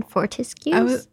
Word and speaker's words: Fortescue's: Or like see all the flavors at Fortescue's. – Oh, yeah. – You know? – Is it Fortescue's? – Florian Fortescue's. – Fortescue's. That's how I've been saying it.